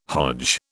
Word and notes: Hodge: British English

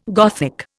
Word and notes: Gothic: Indian

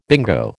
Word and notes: bingo: American English

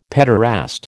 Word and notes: pederast: American English

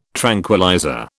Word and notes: tranquillizer: British English

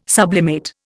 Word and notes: sublimate: Indian

